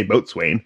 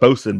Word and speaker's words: boatswain: I see on Wikipedia they're saying like, oh, it's pronounced bosun, but everyone on Black Sails said boatswain. They did not, in fact. That is my brain connecting dots that we're not there. It is bosun, and bosun is and you stop me, you correct me when I am incorrect, because that's the whole goal we're learning here.